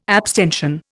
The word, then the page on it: abstention: Indian